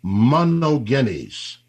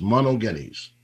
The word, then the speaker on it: monogenes: Now, the word begotten in Greek, I'm going to give you a little Greek now, is monogenes. Say it with me, monogenes, monogenes. That's spelled M-O-N-O-G-E-N-E-S, M-O-N-O-G-E-N-E-S, monogenes.